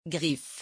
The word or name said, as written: griffe